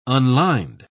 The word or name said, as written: unlined